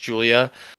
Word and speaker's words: Julija: But yeah, so I'm going to start with, just real quick, Julia Avila versus spelled Julija, but I think it's just pronounced Julia.